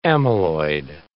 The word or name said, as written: amyloid